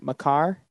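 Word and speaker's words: Makar: McCarr? – Yep.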